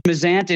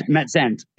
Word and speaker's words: Mizant: It's Mizant. It's the Americanized version. I always tell people P-I-Z-Z-A. How do you say it? – Yeah, right. All right. – So it's Mizant.